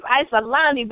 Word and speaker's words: Icielani: Isolani? – Yes, you got it. You got it right. Thank you. Okay, y'all know who it is. Isolani, baby. I-C-I-E-L-A-N-I.